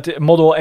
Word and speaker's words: Model: Model Engelse uitspraak.